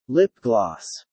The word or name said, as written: lip gloss